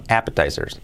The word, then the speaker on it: appetizers: Eh, appetizers, no, appetizers, con acento en la appetizers. Appetizers sería pues un pequeño pues aperitivo, entrante, appetizers. Appetizers, con, la, con, la, con el acento en la A.